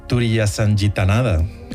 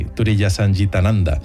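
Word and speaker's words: Turiyasangitananda: Alice sí que siguió en su finca espiritual como maestra hasta su muerte en 2007, se hacía llamar, a ver si lo digo bien, Swamini Turiyasanjitananda. – Ah, te ha salido muy bien. – Casi, Turiyasanjitananda.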